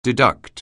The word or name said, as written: deduct